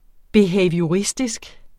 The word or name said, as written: BEHAVIORISTISK